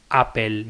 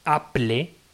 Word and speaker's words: Apple: En inglés hay muchas palabras que tienen una consonante y luego LE, como puede ser paddle, Wimbledon, Apple mismo, o lo que sea, que se escriben como si fueran al revés. Es decir, Apple se escribe Apple, pero se pronuncia Apple.